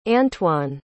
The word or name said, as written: antwan